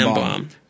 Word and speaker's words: Ambaum: And it's spelled AMBAUM. – Yeah, Ambom.